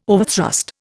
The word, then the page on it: overthrust: Indian